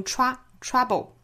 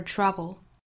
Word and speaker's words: trouble: trouble 前面的 ch 和后面的 r 辅音连缀发 tr，不要把 r 的音丢掉，发成 trouble 是不对的哈。 r 这个发音不要丢了。好，我们来用非常慢的速度用美音来读一下这句话。 I'm only brave when I have to be. Being brave doesn't mean you go looking for trouble.